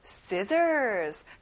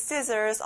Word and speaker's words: scissors: Next, scissors. 가위예요. 친구들, when you use scissors, of course, you have to be careful. 위험해서 아주 조심해야 돼요. 그리고 가운데 C가 들어가는데 그 발음이 안 나요. 신기하죠?